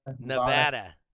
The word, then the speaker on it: Nevada: See, there's an A in Nevada. So Nevada. – That's right. Nevada. Being as I'm from there, we know how to pronounce it correctly.